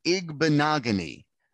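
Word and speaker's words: Igbinoghene: I'm gonna go with Noah Igbinogene. – Igbinogene is one of our most common mispronunciations of this young man's name. It is Igbinogene.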